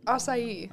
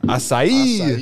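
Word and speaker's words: açaí: So, how do you say this again? – Acai. – Acai.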